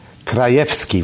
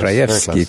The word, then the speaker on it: Krajewski: The first is a papal almoner, and I'll ask your help in making sure that I'm pronouncing this, Cardinal Konrad Krajewski. – Krajewski, very close. – Krajewski, thank you.